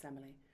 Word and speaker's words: Semele: It's been sung at many a wedding, and that's Where'er You Walk from Handel's Semele, or Semele, I should say. In the English, it's pronounced Semele,